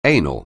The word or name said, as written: anal